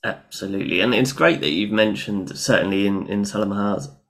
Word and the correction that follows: Sahamalaza: Did I say that right? Salah Yeah, Sahamalaza. – Sahamalaza.